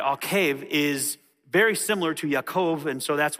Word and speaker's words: Yaakov: His name is Jacob, which technically is pronounced Yaakov. It sounds like the word for heel, which is why they gave him that name.